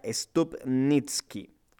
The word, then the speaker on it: Stupnitsky: Vamos a hablar entonces The Good Boys, una película estadounidense de comedia escrita y dirigida por Lee Eisenberg y Gene Stupsnik. Eh, o bueno, eh, a ver, si lo pronunciamos de forma correcta sería Stupnitsky. Así sería el nombre de este señor.